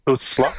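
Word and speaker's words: Stützle: Bruno, je vais juste t'inviter de façon très sympathique et très délicate à travailler sur ta prononciation de Stutzla. Stutzla, qu'on prononce.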